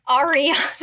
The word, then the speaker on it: Ariana: You got Anira Grande. Honorary Grande. – Ariana.